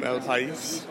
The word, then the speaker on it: El País: So I'm here with Lucia from El País.